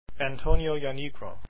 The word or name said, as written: janigro